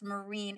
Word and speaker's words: Myrin: But, you know, in real life, you can't spell your name M-Y-R-I-N and have it be pronounced Marine and really care that much.